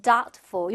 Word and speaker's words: doubtful: 자, 두 번째는 doubtful. 요거 발음할 때는 여기에서 비는 묵음 소리가 나지 않아요. 그래서 그냥 doubt. 이렇게 이어가시면 되고요.